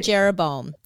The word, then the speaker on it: Jeroboam: And there's Rehoboam and Jeroboam. – Yes, that's it.